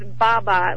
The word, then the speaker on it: Baba: It's Baba, like B-A-B-A. – Daba? – No, B, like B as in boy.